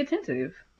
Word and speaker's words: attentive: And be attentive. – Mm. Be attentive and listen. Okay. – You had an extra syllable there. You wasn't listening.